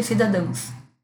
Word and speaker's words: cidadãos: Uma das primeiras apresentações que eu fiz de artigo no Congresso Nacional, que eu estava bem nervosa, eu falei cidadões, e o correto é cidadãos.